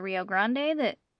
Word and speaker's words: Rio Grande: Areas are in a lush green valley that run along the Rio Grande.